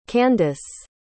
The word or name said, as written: candice